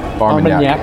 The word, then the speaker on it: Armagnac: We just I don't think me and Mark have ever seen on a bottle finish in, in a, hopefully I'm pronouncing it correctly, Armagnac? – Armagnac. Armagnac. Yeah.